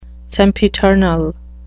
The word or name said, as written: sempiternal